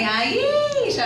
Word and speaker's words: Aisha: My name is Aisha, and I think it's a pretty easy name, right? Two syllables and simple enough, just Aisha. People get it wrong all the time, though.